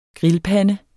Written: grillpande

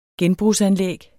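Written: Genbrugsanlæg

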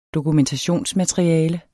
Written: dokumentationsmateriale

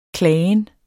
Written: klagen

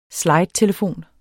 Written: slidetelefon

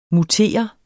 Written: mutere

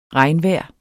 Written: regnvejr

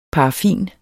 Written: paraffin

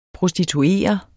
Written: prostituere

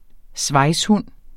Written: svejshund

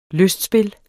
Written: lystspil

